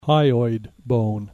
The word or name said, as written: hyoid bone